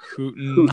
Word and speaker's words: Houghton: It looks like Houghton if you pronounce O-U-G-H as uff every single time. But it's Houghton, Houghton, Houghton.